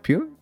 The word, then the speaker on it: Pugh: Eh, no sé bien cómo se dice el apellido. – Pugh, yo le digo Florence Pugh. – Pew, Sí, Pew, eh, que trata sobre.